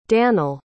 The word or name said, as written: danel